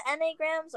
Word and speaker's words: Enneagram: Is it Enneagrams or any, how do you say it? – It's Enneagram.